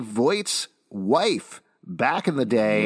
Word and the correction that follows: Vought: Vought's wife. – What? – You said Voight. – Voight. I've been pronouncing it wrong. Vought.